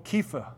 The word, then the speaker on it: Kepha: You will be called Kepha. Now, that's a hard C, not a soft C.